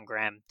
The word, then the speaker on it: Graham: I'm at John Graham Dev, G-R-A-H-A-M Dev.